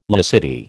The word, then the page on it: laicity: American English